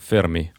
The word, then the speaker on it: Ферми: А, я смотрю на Википедии, э, ударение написано на первый слог. Энрико Ферми. Может быть.